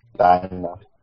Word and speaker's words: Dinah: I'm trying to figure out how is the band already named Black Canary before Diana or Dinah, how you say her name, came along. – Dinah.